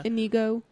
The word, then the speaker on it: Inigo: Is it Indigo or? – Indigo, yeah. – Indigo Montoya. – Inigo Montoya. – Is there a D in there? – I think. Let's see. No, it's Inigo Montoya.